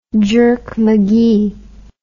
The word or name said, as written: jerk McGee